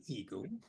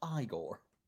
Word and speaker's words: Igor: Shouldn't that be Igor? – No, it's pronounced Igor.